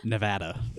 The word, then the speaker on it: Nevada: am I saying it wrong when I say Nevada? – Nevada. – No, you said Nevada multiple times, I think. You said it correctly.